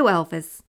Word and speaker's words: Elvis: Sue Elvis. Pardon? How do you spell that? Elvis. E-L-V-I-S. You know, like Elvis Presley.